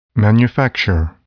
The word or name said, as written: manufacture